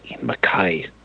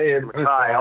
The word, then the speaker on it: Ian MacKaye: Uh, we've talked about him and just make sure, make sure you make sure you uh, pronounce it correctly. It's Ian McKay. – It's right. – Yeah. yeah Ian McKay.